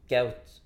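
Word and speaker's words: Kuyt: Men jag måste bara tillägga, för att uttala Keut ordentligt så ska man nästan skära av K-t också. Keut.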